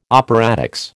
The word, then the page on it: operatics: American English